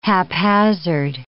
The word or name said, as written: haphazard